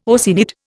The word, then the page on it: Oceanid: Indian